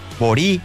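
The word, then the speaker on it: bori: Cuando se acentúa en la penúltima sílaba, en este caso, bo, ri, borí, o en la última realidad, cuando se acentúa en la última, no se escribe la tilde.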